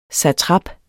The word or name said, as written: SATRAPPENS